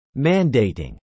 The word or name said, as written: mandating